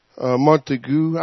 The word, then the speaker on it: Montague: Montague. I never can pronounce that doggone name. M-O-N-T-A-G-U-E.